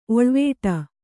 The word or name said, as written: ಓಳಿವಡೆ